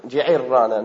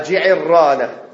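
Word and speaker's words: الجعرانة: Et Jarana, non. – بكسر العين وتشديد الراء. – بارك الله فيك. À Jairana. Donc là, il a dit la même bonne manière de le prononcer, c'est ça, c'est Jairana.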